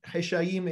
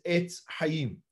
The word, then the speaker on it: Etz Chaim: And they transliterated it in English, H-E-S-A-I-M, because that was the way that they pronounced it. So now we call it the Heshaim, but that's a mispronunciation. Heshaim is Etz Chaim. That's really what it is.